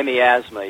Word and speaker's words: miasma: If I could ask a follow-up on that point, early in your book you talk about miasma, I think it is. Is that pronounced correctly? – My miasma, yeah.